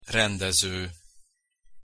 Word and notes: rendezo: Hungarian

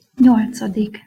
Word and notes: nyolcadik: Hungarian

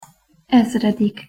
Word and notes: ezredik: Hungarian

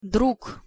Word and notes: drug: Russian

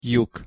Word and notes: lyuk: Hungarian

